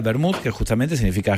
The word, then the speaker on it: Wermut: yo no sé cómo se pronuncia, Mary, pero se escribe wermut. – Vermut. – Ok, ahí está, bien, ahí, con acento en la W.